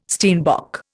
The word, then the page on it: steinbock: Indian